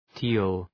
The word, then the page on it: teal: ti:l